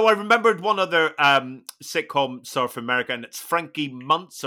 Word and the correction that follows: Muniz: Frankie Muniz, yeah.